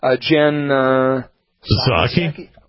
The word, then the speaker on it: Psaki: Jen Psaki.